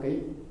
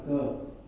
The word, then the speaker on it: cơ: À, ghế được kỹ rồi đó nhưng mà không đọc là kỹ. Chữ này đọc là cơ, không phải kỹ.